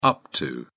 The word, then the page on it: up to: British English